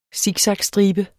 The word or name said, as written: siksakstribe